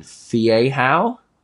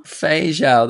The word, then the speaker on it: Feijão: Fiehau? – Oh, wow. No. – No. – Feijão? – Feijão.